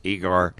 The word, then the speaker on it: Egor: Is it Igor or Igor? – It's spelled E-G-O-R, so... – I got to go with Igor. – I'm saying Igor. – Yeah, it's Igor. You're right.